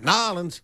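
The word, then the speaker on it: New Orleans: That's about Marie LaVoodoo, the witch queen of Nollins. You got to say it like that. Nollins.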